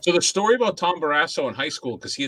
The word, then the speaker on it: Barrasso: I don't know if this guy's really an asshole, but his name rhymes with it. Tom Barrasso.